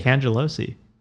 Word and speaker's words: Cangelosi: Cangelosi. – That's more complicated than mine, maybe. – Yeah, but you would not believe how many people really butcher it. Like, really, they add a ski.